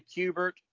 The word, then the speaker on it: Kubert: I say Kubert, and Ron Randall, who was one of the earliest students at the Joe Kubert School, said that I was saying it correctly.